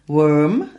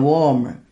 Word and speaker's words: Worm: ท่านผู้ฟังและน้องๆนักศึกษาก็คงจะรู้แล้วตีเนาะว่า A can of worms ก็คือกระป๋องตัวหนอน Worm สกด W O R M อ่านว่า Worm บ่แม่น Warm ที่สกด W A R M